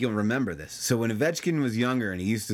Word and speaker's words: Ovechkin: That's, yeah, Ovechkin. – Are you sure you're saying that right? – Yes. – Ovechkin.